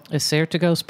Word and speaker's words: Assyrtiko: And Acertico? – Acertico's perfect. – Okay. – A-S-S-Y-R-T-I-K-O.